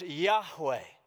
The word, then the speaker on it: Yahweh: YHWH would have likely been, uh, been pronounced Yahweh.